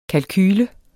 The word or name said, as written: kalkule